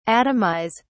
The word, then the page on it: atomise: British English